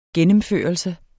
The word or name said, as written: gennemførelse